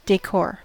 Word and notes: decor: American English

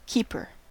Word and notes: keeper: American English

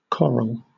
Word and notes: coral: British English

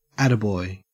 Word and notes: attaboy: Australian English

